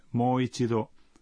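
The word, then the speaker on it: Moichido (もう一度): Observen que la sílaba mo en Moichido se pronuncia prolongada.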